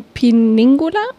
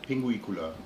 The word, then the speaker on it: Pinguicula: Du hattest mir auch von dem Piningula, ist das richtig ausgesprochen? Pinguicula.